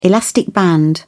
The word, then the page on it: elastic band: British English